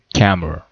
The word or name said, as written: camera